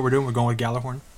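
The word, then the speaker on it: Gjallarhorn: So we're going to, for the purposes of this episode, I think we're mostly going to stick with Galahorn since that's what comes out of Bungie. That's how they say it over there, so let's say that over here.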